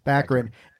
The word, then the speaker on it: Baccarin: Baccarin.